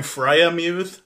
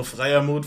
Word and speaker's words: Freiermuth: Also die, die Amis sagen Freiermuth, aber es ist natürlich ein deutscher Name, also Freiermuth finde ich auch gut.